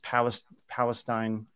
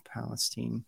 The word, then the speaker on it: Palestine: Basically, I mean, where it happened was, it was East Palestine, Ohio. That's the way you pronounce it, not Palestine.